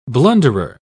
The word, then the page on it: blunderer: British English